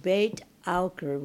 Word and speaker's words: Beit Aukar: In fact, in Lebanon, there's two villages in Lebanon, in the north, beautiful area in the north, called Beit Aukar, which is the way you would pronounce it in Arabic, and, House of Okar.